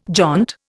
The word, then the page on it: jaunt: Indian